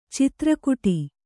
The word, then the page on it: ಚಿತ್ರಕುಟಿ: citra kuṭi